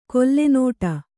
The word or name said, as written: ಕೊಲ್ಲೆನೋಟ